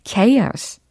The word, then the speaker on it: chaos: chaos, chaos. 이렇게 발음을 하시면 되겠고요.